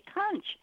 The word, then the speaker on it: conch: Yes, it's spelled C-O-N-C-H, so the natural tendency is to say conch, but the actual pronunciation is conch. It's one of those really unusual words, and I've never heard anybody say it correctly.